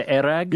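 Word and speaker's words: airag: I have had many a crack at so they pronounce it their erag. – Yeah. – When I was in Kazakhstan, another place, they call it kumis, but in Mongolia, it's erag.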